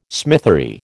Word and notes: smithery: American English

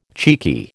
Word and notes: cheeky: American English

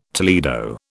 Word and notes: Toledo: British English

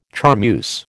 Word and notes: charmeuse: American English

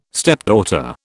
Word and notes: stepdaughter: British English

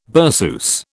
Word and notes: berceuse: British English

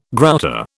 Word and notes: grouter: British English

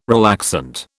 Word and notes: relaxant: British English

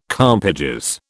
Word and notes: compages: British English

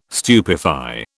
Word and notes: stupefy: British English

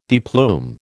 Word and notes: deplume: American English

